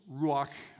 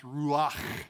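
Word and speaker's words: ruach (רוח): So it's ruach. It's really pronounced ruach. Those of you taking notes, is spelled R-U-phlegm-H. Ruach. I'm just going to go ruach because it hurts my throat, but now you know the true pronunciation.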